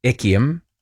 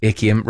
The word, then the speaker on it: Echium: The plant is Embeckia. It's not how you spell it. No, it's Echium. Another text, Echium.